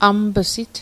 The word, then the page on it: amip'sit: Mi'gmaq